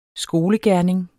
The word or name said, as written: skolegerning